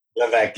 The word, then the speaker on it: Levesque: Or I don't know how to say his last name, Levesque. – Levesque, yes, it's somewhere in there. – Silent S, yeah. – But it's French last name. – Yeah, it's French guys.